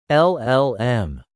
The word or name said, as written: LLM